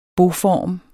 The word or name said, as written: boform